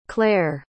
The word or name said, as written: clare